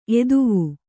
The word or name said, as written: ಎದವು